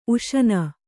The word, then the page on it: ಊಷಣ: ūṣaṇa